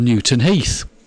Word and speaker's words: Newton Heath: It's actually United's original name, because that's where the club was formed, called Newton Heath, which rhymes with teeth.